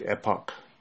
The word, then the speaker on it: epoch: Well, this one says epoch. – That's how you pronounce it? – Not epic, epoch.